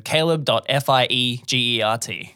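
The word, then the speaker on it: Caleb Fiegert: So F-I-E-G-E-R-T. So caleb.fiegert.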